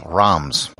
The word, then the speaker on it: Reims: The main city in the Champagne region is Reims, or as it's often pronounced in English, Reims.